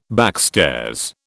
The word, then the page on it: backstairs: British English